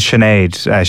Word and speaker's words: Sinead: As you said, it is Sinead. She was born in England, but to give her a phonetic spelling, her name is spelled S-H-A-N-A-D-E, which is certainly a first for me, Sue.